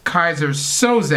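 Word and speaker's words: Keyser Söze: And also on the other side of that same comic, there was Kaiser Soze, I hope I'm saying that right. – Yep.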